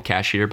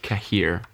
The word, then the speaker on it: Cahier: People call them Cashier, but there's not actually an S there. It's like Cahier or something like that.